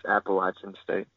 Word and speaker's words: Appalachian State: Is it Appalachian State or is it Appalachian State? Settle this debate for us. – It's Appalachian State. And up there in the mountains, if you pronounce it wrong, people do not like it.